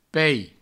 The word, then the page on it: peis: provencal